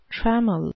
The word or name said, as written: trammel